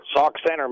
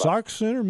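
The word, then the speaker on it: Sauk Centre: Sauk Center, Minnesota. – Sauk Center, Minnesota.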